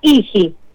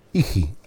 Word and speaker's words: hygge: Sí, H-Y-G-G-E. – Ah, hijito, no, Iji. Bueno, ¿qué quiere decir? – Yo la pronuncio así, cada uno la pronuncia, tiene una pronunciación rara, entonces viste, hay algunos que la pronuncian de otra manera, otros de otra, yo le digo Iji. – Deletréela de vuelta, a ver. – H-Y-G-G-E.